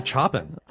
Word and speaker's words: Chopin: It's just actually Chopin, if I'm not... But the Chopin, that's a French pronunciation. – Yes, that's the French pronunciation, but Polish is... Friedrich, blah, blah, blah, blah. Anyway. – And it's spelled just like C-H-O-P-I-N, like yeah.